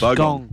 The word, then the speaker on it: Bogong: Bogong moths. – Bogong. – Bogong. – Bogong. – Bogong. – Bogong. – Gong. Gong, as in Wollongong.